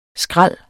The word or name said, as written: skrald